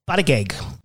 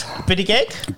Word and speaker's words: Buttigieg: She obviously hates Trump, but she's a big fan of Pete Buttigieg. Did I say it right? – Button guts. – Buttigieg? – Buttigieg.